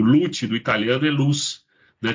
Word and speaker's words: Luce: A gente escreve a palavra como Luce, a pronúncia é Lute porque é italiano, e tem uma, um porquê.